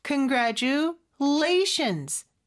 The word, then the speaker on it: congratulations: 그리고 gra 요 부분에 강세가 있어요. Congratulations 또 더 강한 강세가 또 뒤에 있죠. 그래서 congratulations라는 거 이거 기억해 주시고 congratulations on your 이렇게 연음이 두 번 됩니다.